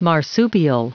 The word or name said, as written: marsupial